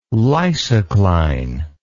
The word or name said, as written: lysocline